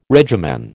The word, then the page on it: regimen: American English